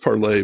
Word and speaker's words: Parler: So he was a big backer of Parler. Well, it's supposed to be pronounced Parlay, but no one does.